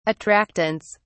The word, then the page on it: attractants: British English